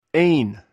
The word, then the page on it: hn: greek